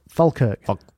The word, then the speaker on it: Falkirk: Falkirk, right, how do you say it? – Falkirk. – It's Falkirk. – Falkirk. – We're supposedly saying it wrong, yeah, so Sean.